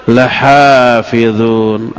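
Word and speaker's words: Lahafidhun (لحافظون): Pertama dengan menggunakan huruf taukid, inna, kata Allah, wa inna lahu lahafidhun. Inna dengan nahnu, inna itu huruf taukid. Jadi inna, taukid artinya apa? Sesungguhnya. Memastikan, menekankan. Kedua, adanya lam taukid. Lahafidhun adalah lahnya dibaca pendek, tidak boleh panjang. Wa inna lahu lahafidhun.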